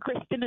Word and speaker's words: Kristin: That's right, and Kristen is spelled K-R-I-S-T-I-N.